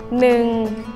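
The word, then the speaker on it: nừng (หนึ่ง): số 1 đọc là nừng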